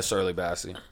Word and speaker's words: Shirley Bassey: Yeah, Shirley Bassey.